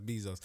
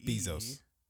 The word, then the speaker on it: Bezos: But I hate the way you say his name, Bezos? By the way. Yeah. – Bezos. Is it Bezos? Bezos? Is there an A in there? – No. – Bezos? – Just B-E-Z-O-S. Bezos.